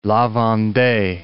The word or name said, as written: La Vendee